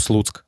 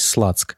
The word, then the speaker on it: Слуцк: а конкретно на футбольный клуб «Слуцк». Дело в том, что в английской транскрипции он пишется S-L-U-T-S-K, то есть «Слацк».